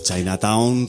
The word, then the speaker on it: Chinataun: Pero Chinatown, como se escribe fonéticamente, C-H-I-N-A-T-A-U-N. Chinatown, con las producciones de Asier y Tian, los sketches de DJ Ted Bundy y las rimas de Lactus y Piel Roja.